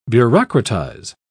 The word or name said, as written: bureaucratize